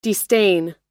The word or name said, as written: destain